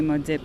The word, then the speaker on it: Maudite: because the correct pronunciation is on the sign, and you're like providing a community service telling everybody how to pronounce Maudite. – We like to offer that with a sidebar of that being the U.S. pronunciation, because if you're coming from Canada and you're from Quebec, you're going to say Maudite Poutine.